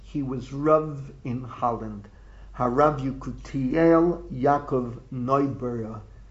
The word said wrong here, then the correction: Neubauer: Neubauer. I should pronounce it Neubauer.